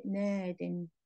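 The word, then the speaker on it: نادٍ: However, we only put the long E if the word is defined. If it's not defined, you say نادن. – Nadin.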